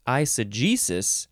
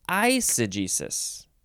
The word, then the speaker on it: eisegesis: We got, oh, you can, okay, so you're either emphasizing the j, the g, eisegesis, or you're emphasizing the first syllable, eisegesis.